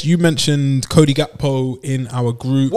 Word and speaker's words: Gakpo: Cody Gakpo.